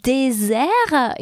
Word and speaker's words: désert: Un désert in French, this word is spelled with a single S and it's surrounded by two vowels, so twice the letter E. Therefore, the S is sounded Z.